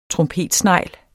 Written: trompetsnegl

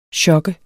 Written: chokke

